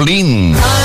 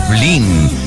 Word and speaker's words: Lynne: George Harrison y Jeff Lynne. Sí, sí, sí, he dicho Jeff Lynne. La mayoría de la gente dice Jeff Lynn, pero no está bien pronunciado. Es Jeff Lynne.